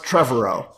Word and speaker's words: Trevorrow: Colin, I believe it's pronounced Trevorrow, although whenever I see it... – I knew that Trevor was in there somehow. Yeah. – I want to say tomorrow whenever I see the word, because it looks like yes.